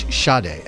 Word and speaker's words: Sade: I think it's Sade. S-A-D-E.